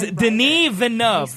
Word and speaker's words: Denis Villeneuve: His name is Denis Villeneuve.